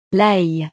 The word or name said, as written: l'ail